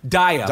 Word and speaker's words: Daya: How do you say her name? – Daya. Daya is the character named Dasha. – Dasha, right. Okay, Daya.